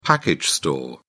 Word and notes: package store: British English

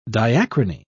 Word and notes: diachrony: British English

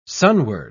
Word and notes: sunward: British English

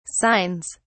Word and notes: signs: British English

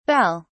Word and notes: belles: British English

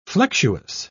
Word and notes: flexuous: British English

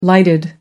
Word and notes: lighted: American English

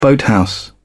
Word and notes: boathouse: British English

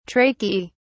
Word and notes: tracheae: British English

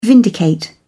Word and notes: vindicate: British English

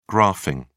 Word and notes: graphing: British English

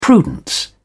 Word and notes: prudence: British English

